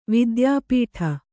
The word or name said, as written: ವಿದ್ಯಾಪೀಠ